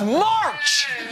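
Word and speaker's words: Hunter March: Sorry, Hunter Marsh. – It's March! Hard C!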